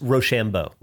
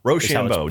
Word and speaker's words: Rocheleau: It's from Joseph Rochelleau. I'm not sure how to say it. R-O-C-H-E-L-E-A-U. – It's Rochambeau. – Rochambeau. Joseph Rochambeau.